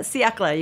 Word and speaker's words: siècle: But siècle, you can actually pronounce all the letters, which is rare in French. – Well, let's spell it. It's S-I-E-C-L-E. – Correct.